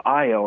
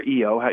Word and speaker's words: Io: And in the case of Io or Eo, you know, people I've heard it pronounced both ways, it's incredibly volcanic, and it spews sulfur up into space.